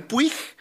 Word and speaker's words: Puig: Dije, güey, como el libro de Manuel, que algunos lo pronuncian Puig, pero quienes lo conocen, lo conocieron, Manuel Puig, okay, del argentino Manuel Puig, acerca de dos presos en una cárcel, en una dictadura.